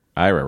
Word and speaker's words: Ira: Ira rhymes with Myra.